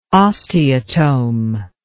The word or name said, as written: osteotome